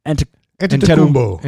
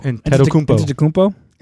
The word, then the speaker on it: Antetokounmpo: Antetokounmpo. – Antetokounmpo. – Antetokounmpo. – Is there a B in there? Tagovailoa Antetokounmpo.